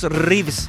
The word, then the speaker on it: Reeves: Mira a Matías, a Matías Reeves. Se pronuncia arriba así como... como el actor de John Wick.